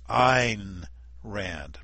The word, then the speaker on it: Ayn Rand: It's Ayn Rand, pronounced like nine. Ayn Rand.